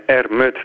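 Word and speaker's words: R. Mutt: en R.mut namelijk ook fonetisch uitgesproken kan worden als armoed. het Duitse woord voor armoede.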